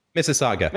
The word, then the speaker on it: Mississauga: He lives in Canada in a place called Mississauga. Am I saying that correctly, Stéphane? – Mississauga.